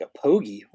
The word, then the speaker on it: apogee: I'm like, is it pronounced Apogee? He's like, no, Apogee. It's like the height of a satellite in its orbit. Like it's the highest peak of its orbit.